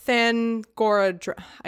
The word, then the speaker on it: Thangorodrim: Thus they roused from sleep a thing of terror that, flying from Thangorandrim, Th- Thangorodrim, I could never say that right, but that Thangorandrim was essentially Morgoth's or Melkor's evil fortress in the Silmarillion.